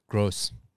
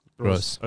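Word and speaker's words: Groß: Gross. Gross.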